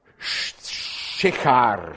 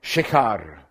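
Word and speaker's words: Sychar: Da kam er in eine Stadt Samariens, die heißt Schechar, spricht man das aus. Ich habe es extra nachgeschaut. Schechar.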